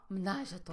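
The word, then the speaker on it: ménage à trois: Menage a Trois.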